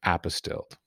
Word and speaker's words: apostille: Do we need apostille? How is it pronounced? – Apostille. – Okay, yes. Nobody's ever told me how that's pronounced.